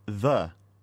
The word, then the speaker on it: dhe: You'll also notice that in this lesson there's the word the, D-H-E, pronounced like the English word the.